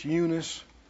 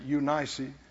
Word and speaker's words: Eunice: It dwelt first in your grandmother, Lois, and in your mother, some pronounce this Eunice, some of the things pronounce it Eunice.